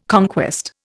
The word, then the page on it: conquest: Indian